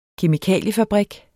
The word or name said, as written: kemikaliefabrik